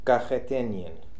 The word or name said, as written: Kakheteenian